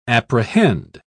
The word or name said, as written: apprehend